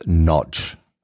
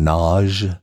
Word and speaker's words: Nagy: Also alle sagen es ein bisschen anders. Offiziell habe ich mal gelesen, spricht man es aus Notch. Ja? Das ist die ungarische Art. Die Akademiker in den USA sagen Nage. Und die Deutschen natürlich Nagi.